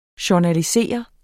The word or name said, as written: journalisere